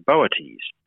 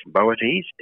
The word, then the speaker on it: Boötes: And there's another one, a reddish sort of coloured one, called Arcturus, which is the brightest star in the constellation of Boötes. That's not a constellation most people are familiar with, Boötes. It's spelled B-O-O-T-E-S, and it means the herdsman or the ploughman.